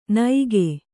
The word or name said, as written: ನೈಗೆ